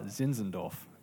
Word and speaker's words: Zinzendorf: I know I said it right, Zinzendorf.